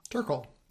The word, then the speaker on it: Turkle: In the TechWise family, Andy, you note that Sherry, is it pronounced Turkle, Turkel? – Turkle.